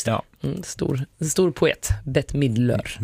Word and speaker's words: Midler: Bets Midler, som jag får hjälp att uttala hennes efternamn, M-I-D-D-L-Ö-R, Midler. Midler, så att det inte blir Midler.